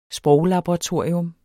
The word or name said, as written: sproglaboratorier